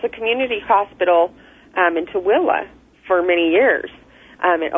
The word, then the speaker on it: Tooele: Am I pronouncing it correctly? Tule Valley? – It's Tooele. – Tooele. pronounce it Thule. That's what it's spelled.